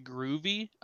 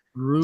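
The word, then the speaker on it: Albin Grewe: Yeah, and I'll round out the draft selections with Albin, whose now last name is pronounced Groovy, apparently. – Groovy. Is that what they said today? I was trying to figure that out. – Yeah, the W makes a V sound because he's Swedish. So it's Groovy.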